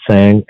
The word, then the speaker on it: Tsang: My name is Alan Tsang, A-L-L-A-N. Last name is T-S-A-N-G, silent T-S-A-N-G.